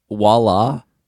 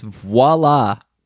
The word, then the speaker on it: voila: I hate it when Americans pronounce French words wrong. Like, voila. Have you heard people do that? – That's like a, they're like mixing in some Russian. – Yeah. – When you pronounce a V as a W. – It's voila, Americans. It starts with a V.